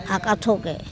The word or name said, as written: akatHoke